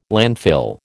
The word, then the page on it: landfill: American English